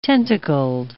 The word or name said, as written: tentacled